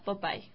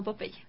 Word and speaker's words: Popeye: Aunque su nombre en español se pronuncia literalmente en inglés, se pronuncia, en inglés se pronuncia Popeye, no Popeye.